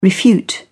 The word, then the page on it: refute: British English